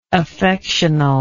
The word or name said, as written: affectionally